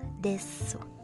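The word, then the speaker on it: Des (です): desu Desu ya Desu tapi dibacanya Des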